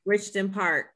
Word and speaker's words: Richton Park: Let me pronounce this right. Richter Park. Am I saying that right? – Mm-hmm. Richton Park.